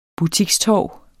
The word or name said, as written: butikstorv